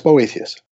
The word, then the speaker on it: Boethius: Yeah, it's Boethius.